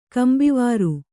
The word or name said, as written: ಕಂಬಿವಾರು